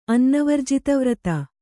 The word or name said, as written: ಅನ್ನವರ್ಜಿತವ್ರತ